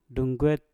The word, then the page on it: dunget: Mpur